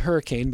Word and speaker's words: Hurricane: So I lived, when I lived in West Virginia, I lived in a town called Hurricane, which is spelled hurricane, but it's pronounced Hurricane. It's like a syllable and a half.